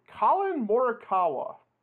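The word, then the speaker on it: Collin Morikawa: Colin Morikawa.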